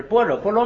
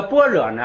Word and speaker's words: 般若: 先解释般若，这个般若呢，你去查新华字典啊，它就标着念般若。实际上那个梵文的那个词的发音呢，更接近于般若，哎，这个就是它那个梵文的发音。但是呢，我们那个这跟那个古音有关系啊，我们古祖先传下来读般若，我们就顺随他读般若。